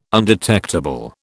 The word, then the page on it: undetectable: British English